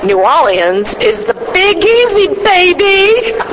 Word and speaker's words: New Orleans: That's how you pronounce it, right? – No. – All right, so how do you pronounce it? Let's do it right. – All right, let's do it right. Drum roll, please. It's New Orleans.